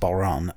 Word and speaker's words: bodhrán: Robbie Walsh, the bodhrán, I'm saying bodhrán wrong. It's actually pronounced Balron, I think.